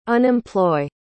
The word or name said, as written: unemploy